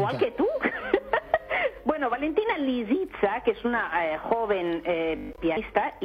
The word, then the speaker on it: Lisitsa: que está interpretada por Valentina Lisitsa. Lo he dicho bien. – No, lo has dicho, lo has dicho fatal. – A ver cómo es. – Pues hombre, Lisitsa. – ¿Lisitsa? – Lisitsa. Lisitsa, con S.